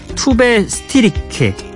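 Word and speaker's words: Tove Styrke: 블라스코의 Another Love 그리고 토브 스트라이크의 Say, Me, Say My Name, Say My Name 예, 토브 스트리크 예, 발음이 좀 어렵죠. 톱 스티리크 그리고 스웨덴 발음으로는 투베 스티리케 스티르케 아우 이것도 어렵네요.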